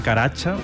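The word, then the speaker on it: Karaca: No, este es Cem Caracha, que se escribe Cem Caraca, si lo queréis buscar.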